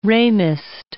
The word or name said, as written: Ramist